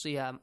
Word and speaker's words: صيام: And siyam, the letter sad, must be pronounced correctly. It's a bit different from the letter sin, right? It has kind of a whistling sound, but not completely whistling sound, okay?